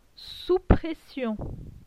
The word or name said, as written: sous pression